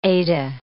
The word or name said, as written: Ada